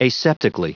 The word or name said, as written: aseptically